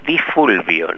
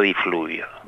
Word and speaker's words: Di Fulvio: Vamos a contarles a los oyentes que Carlos Di Fluvio se estará presentando mañana. – Yo soy Carlos Di Fulvio, no Di Fluvio.